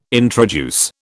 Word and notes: introduce: British English

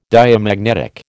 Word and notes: Diamagnetic: American English

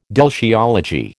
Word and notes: deltiology: American English

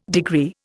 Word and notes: degree: Indian

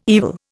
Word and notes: evil: Indian

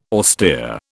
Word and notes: austere: British English

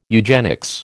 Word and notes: eugenics: American English

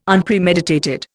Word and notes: Unpremeditated: Indian

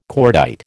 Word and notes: cordite: American English

